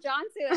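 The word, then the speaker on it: Cansu: And I saw that her name started with a C, and I'm like, you're Turkish. Because it's pronounced Jansu.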